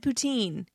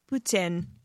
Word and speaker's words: poutine: If you said poutine, while that is sort of right, that's not how it's pronounced. It is poutine.